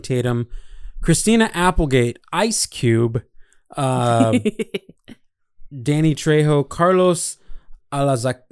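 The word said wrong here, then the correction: Alazraqui: I've never learned how to pronounce his last name. Carlos Alazraque, the voice of what's his name?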